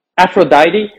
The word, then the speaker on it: Aphrodite: Aphrodite.